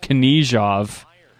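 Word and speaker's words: Knyazev: I don't know if I've always pronounced the name correctly, but now we heard it from Brett Hedekin. It's pronounced Knyazhov. I'm trying not to make that mistake again.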